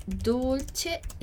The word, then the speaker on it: Dolce: Dolce, sempre o som do nosso C aqui, né? C, E. Dolce.